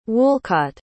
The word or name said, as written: woolcott